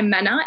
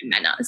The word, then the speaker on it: Mena: And it's Mena, right? Like the Spanish pronunciation. – Well, my full name is Jimena. – Mm. – But it's sometimes hard to pronounce. So I just go by Mena, and my family goes by Mena.